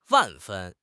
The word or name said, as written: 万分